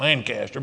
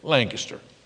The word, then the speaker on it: Lancaster: Outside of southeastern Pennsylvania, that's where it's pronounced, Lancaster. But there, it's Lancaster. So